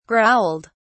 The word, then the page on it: growled: British English